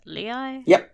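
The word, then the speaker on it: Lehi: From Lehigh, Utah. Is that how you say it? Lehigh? – Yep.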